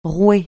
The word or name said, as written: rouer